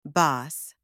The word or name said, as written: boss